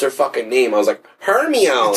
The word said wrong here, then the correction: Hermione: Dude, I couldn't pronounce her name. – Hermione. Hermione. Like, I didn't know it was Hermione.